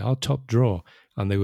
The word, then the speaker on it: top drawer: And of course, it's like when Spanish speakers see that particular word, they're probably in many cases are very tempted to kind of pronounce that ER at the end, like drawer, right? – Yeah, but actually, it's the ER at the end there is completely silent. So it'd be top drawer, just like the verb, the dibujar, right? – Exactly.